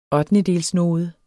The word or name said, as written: ottendedelsnode